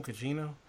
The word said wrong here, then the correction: Gugino: I'm I'm always up for Carla Gugino, but it, it has to be on DVD where I can freeze frame it.